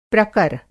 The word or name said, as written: प्रकर